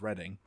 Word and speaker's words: Reading: I didn't know it was Reading. – I'm like 95% sure it is Reading.